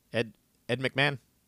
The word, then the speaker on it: Ed McMahon: Ed McMahon.